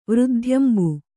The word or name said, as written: ವೃದ್ಧ್ಯಂಬು